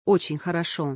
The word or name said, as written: Очень хорошо